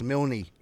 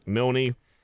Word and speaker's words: Milne: His friend's name's Dax Milane. Milane, I don't know how the fuck you pronounce his last name. – Dax Milne. – Milne.